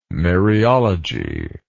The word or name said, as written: Maryology